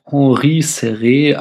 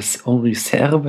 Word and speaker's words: Henri Serre: Mm, in der Besetzung sehen wir Oscar Werner als Jules, Henri Serré als, oder Henri Serre wahrscheinlich eher ausgesprochen, als Jim und Jeanne Moreau als Catherine.